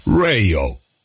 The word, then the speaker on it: Rayo: Se on ilmainen. R-A-Y-O. Reijo.